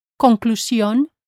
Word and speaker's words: conclusión: Notice how I pronounce the S-I-O-N in words like conclusión, confusión, decisión, excursión, explosión, televisión, versión. Avoid making the English shun sound.